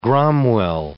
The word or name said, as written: gromwell